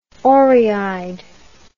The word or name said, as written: orry-eyed